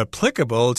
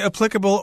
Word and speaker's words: applicable: She says, I thought tax refunds are applicable to alcohol. – Ah. – You could say applicable or applicable. Both pronunciations are common.